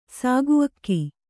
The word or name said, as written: ಸಾಗುಅಕ್ಕಿ